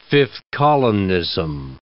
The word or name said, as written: fifth columnism